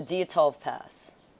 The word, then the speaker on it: Dyatlov: The Dyatlov Pass.